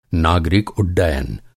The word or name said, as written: उड्डयन